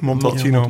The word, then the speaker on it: Montepulciano: in Montepulciano, hè? zeg ik het goed? – Montepulciano.